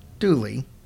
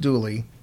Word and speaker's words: Dula: A man named Tom Dula, pronounced Dooley, but it's D-U-L-A is how it's mm-hmm. spelled, but it's pronounced Dooley, was convicted and hanged for the crime.